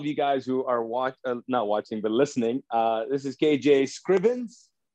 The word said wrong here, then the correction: Scriven: Well, no S on the end of the Scriven, but so it's just Scriven, KJ Scriven, but close, bro.